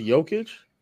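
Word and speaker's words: Jokić: That's hey, from Drink More Water, shout out to Water, how long will it take them to realize Jay and Jokic is silent? I forget. – So you forget that it's pronounced Jokic? – I say Jokic and Jokic sometimes.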